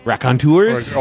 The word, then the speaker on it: Raconteurs: It's the Raconteurs? – Raconteurs? – Okay, I've been saying it wrong my entire life.